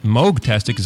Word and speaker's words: Moogtastic: Moogtastic is how you pronounce it.